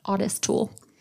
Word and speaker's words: Ottis: You pronounce it Ottis because there's two T's in it. Ottis Toole.